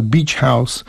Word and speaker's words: Beach House: Beachhead się nazywa, zespół się nazywa Beach House.